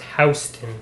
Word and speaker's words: Houston: Yeah, but I mean, they pronounce it Houston Street down in New York, so... – Well, we're not in Houston. – Houston Street is pronounced Houston in New York.